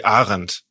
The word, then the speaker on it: Arendt: Arndt, Arndt, Hannah Arndt. – Yeah, I, I, mean, she was of German descent, so probably Arendt.